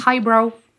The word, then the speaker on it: highbrow: Highbrow, lowbrow. – Mhm. – Bro, tak wymawiamy, tak? – Brow, brow, low brow. – Brow, brow, brow, takie A. – Mhm. Highbrow, lowbrow.